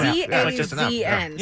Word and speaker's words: DAZN: D A Z N. So you know, that's how they pronounce D-A-Z-N.